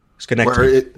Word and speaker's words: Synecdoche: Schenectady. – It's spelled Synecdoche, but I guess it's Schenectady.